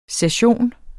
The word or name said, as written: session